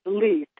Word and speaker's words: Leith: Pronounce the town name for me. Is it Leith? – It is Leith, L-E-I-T-H.